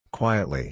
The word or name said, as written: quietly